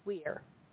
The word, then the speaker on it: Weyr: Wire Weir is spelled W-E-Y-R, although I think it would be the same as W-E-I-R. So she says a pronunciation consensus for W-E-I-R by default, of course people are free to do it however they want with their own names, is Weir. So W-E-Y-R is probably also Weir.